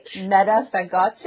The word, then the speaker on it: Meta Fagace: Maybe now would be a good time to talk about your latest album, and I believe I'm pronouncing it correctly, Meta Fagace, yeah. Is that right? – Yeah, it is.